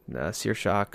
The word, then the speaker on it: Saoirse: And I think Saoirse could definitely play that role pretty well.